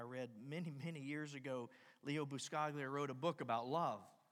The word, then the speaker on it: Buscaglia: Leo Buscaglia.